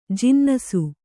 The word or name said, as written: ಜಿನ್ನಸು